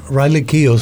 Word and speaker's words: Keough: Riley Keough, ¿así se pronuncia? – Riley Keough, sí.